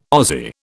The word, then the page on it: Aussie: British English